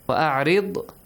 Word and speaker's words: وَأَعْرِضْ: Only the letter Ra has to be pronounced correctly. وَأْمُرْ heavily. وَأَعْرِضْ ri lightly. Yes?